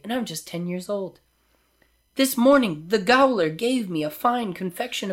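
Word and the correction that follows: gaoler: Jailer. – Oh, is really? – Yeah, it's an old-timey word for jail, but it's pronounced the same.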